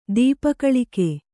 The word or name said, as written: ದೀಪಕಲಿಕೆ